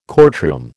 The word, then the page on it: courtroom: American English